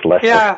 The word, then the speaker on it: Leicester: I can't pronounce it. How do you say it? – It's Leicester.